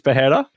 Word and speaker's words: Pereira: So, Pereira, I think that's how you pronounce it, right? It's not Pereira, it's Pereira. – Pereira.